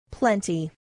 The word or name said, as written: plenty